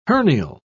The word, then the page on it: hernial: British English